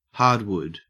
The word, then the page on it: hardwood: Australian English